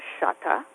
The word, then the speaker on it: Shutta: Anyway, it's pronounced Shutta, as in shut the door.